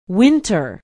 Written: winter

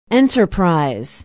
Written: enterprise

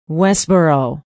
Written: westboro

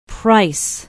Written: price

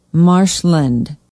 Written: marshland

